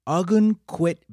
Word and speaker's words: Ogunquit: So they get there, and it's a place called Ogunquit Beach. It's spelled O-G-U-N-Q-U-I-T. Like Ogun. – Right. – Like Ogun quit.